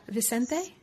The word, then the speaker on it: Vicente: Is that Vicente? – Vicente, yeah. – Vicente, mm-hmm. The Italian-American in me wants to, like, pronounce all the C's as cha, you know, Vicente, right? Like, mozzarella.